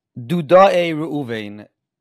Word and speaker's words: Dudae Ruvain: Devadai Ruvain. I'm for sure saying it wrong. – Dudae Ruvain. Named after the Dudaim.